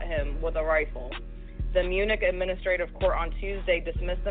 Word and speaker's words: Munich: Munich.